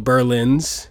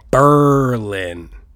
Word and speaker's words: Berlin: There's a couple of Berlins in Connecticut, Massachusetts, New York, and in Wisconsin, and they pronounce it Berlin. Berlin. Like Aaron Burr, sir. Berlin.